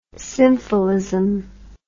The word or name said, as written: symphilism